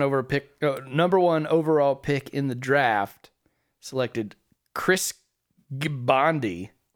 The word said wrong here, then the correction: Gbandi: It's GBANDI.